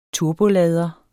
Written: turbolader

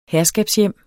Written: herskabshjem

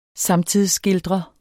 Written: samtidsskildrer

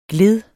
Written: gled

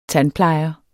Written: tandplejer

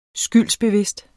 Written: SKYLDBEVIDST

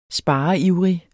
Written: spareivrig

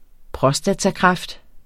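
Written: prostatakræft

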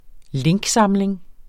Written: linksamling

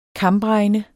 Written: kambregne

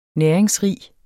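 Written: næringsrige

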